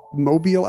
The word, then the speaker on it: Mobile: I said that wrong before. – Mobile.